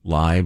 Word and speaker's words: Lai: I believe it's Lai, but...